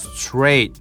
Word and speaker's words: straight: 特に T から R の組み合わせで、ね、 R は唇を丸めて下引いたウーって音なので、ちょっとこのウ感があるよね。だからでツッツッと混ざるとチュッチュッチュッ Straight, straight. で、 straight の T は F とぶつかって消えちゃうからね、まあほぼ発音しません。